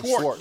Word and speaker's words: Schwartz: And I think Jim Schwartz, am I saying his name right? Is it Schwartz or Schwartz? – Schwartz. Yeah, you got it. Schwartz.